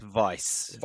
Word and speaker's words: Weiss: Weiss spelt W-E-I-S-S. When I would say that that's pronounced Vice.